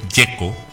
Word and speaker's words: Gieco: Raúl Alberto Antonio, voy a decirlo una sola vez en invero italiano, Raúl Alberto Antonio Gieco,